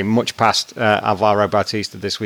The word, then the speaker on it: Alvaro Bautista: Alvaro Bautista.